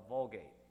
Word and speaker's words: Vulgate: was called the Vulgate. It rhymes with Colgate, like the toothpaste, but it's with a V. Vulgate.